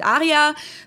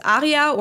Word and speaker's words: Arya: So it's both called Jay and Aria, or Aria, depending on how you want to pronounce it. For those of you watching, I'm going to put it on the screen. For those of you listening, it's spelled J-A-Y and A-R-Y-A. So that's how you spell Aria.